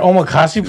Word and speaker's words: omakase: Or their omakase place. Am I saying it right? – Yeah.